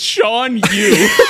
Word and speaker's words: Shan Yu: You nailed it the first time. It's Shan Yu.